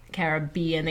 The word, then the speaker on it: Caribbean: But, yeah, the Caribbean, the accent is on B.